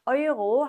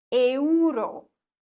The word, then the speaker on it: euro: Zum Beispiel das Wort Euro heißt auf Spanisch EURO. EURO. Also das kannst du dir gleich merken für die Vokale im Spanischen. Wenn zwei hintereinander stehen, dann werden sie einfach getrennt voneinander auch ausgesprochen.